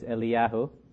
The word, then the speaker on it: Eliyahu: The Hebrew it's Eliyahu, and the second is Elisha.